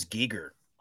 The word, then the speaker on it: Giger: I've always said H.R. Giger. – He pronounces it as Giger. – I never knew that. Okay. – Yeah, I thought Geiger as well, because I think of, like, a Geiger counter.